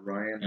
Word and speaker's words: Rian Johnson: Yeah, Ryan.